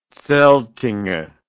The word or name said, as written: zeltinger